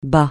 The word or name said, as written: bas